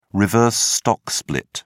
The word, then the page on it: reverse stock split: British English